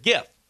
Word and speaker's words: GIF: Abner would like to know, the word GIF, the computer term, is it a soft G like giraffe, hard G like game, or a silent G like Nat? – It's a hard G. – 64.5% of you said GIF.